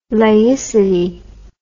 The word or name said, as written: laicity